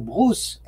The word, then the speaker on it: Brousse: É, essa, essa brochura do, do Paul Bruce era, ou Brousse, como se escreve, com dois S, era uma polêmica interna na formação é, de partidos na Suíça e na França.